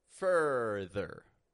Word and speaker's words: further: When we say further, further, I'm not even pronouncing that U as a regular U, even though it's spelled F-U-R-T-H-E-R, I'm not pronouncing it like a regular U, farther. That's too exaggerated. Instead, I'm keeping my teeth very close together. Fur, fur, further, further, further. This is that schwa R sound.